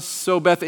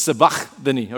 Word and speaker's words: Sabachthani: First time I was like, Eli, Lama, so Beth. Yeah, it was just bad. All right. But here's how you say it. Okay. Generally. Ali, Ali, Lema, Sabachthani. Okay. If you really want to be about it, it's Sabachthani. Okay.